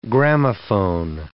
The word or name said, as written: gramophone